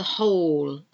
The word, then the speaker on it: whole: The whole. The whole thing is about silent letters. W-H-O-O-L-E. W-H-O-L-E. Whole. So again, you don't pronounce the W. Write. I'm going to write something down now. And this write is W-R-I-T-E.